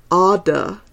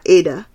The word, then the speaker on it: Ada: It's Ada, not Ada, as it looks. You rhyme it with Ardor.